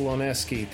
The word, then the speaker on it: Loniewski: And joining us today to talk about his comic Gung Ho, we welcome Michael Loneski.